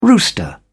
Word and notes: rooster: British English

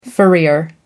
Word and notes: furrier: American English

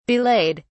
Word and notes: belayed: British English

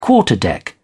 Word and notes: quarterdeck: British English

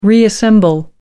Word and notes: reassemble: American English

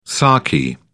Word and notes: sarky: British English

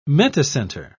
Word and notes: metacenter: British English

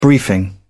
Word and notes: briefing: British English

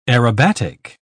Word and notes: aerobatic: British English

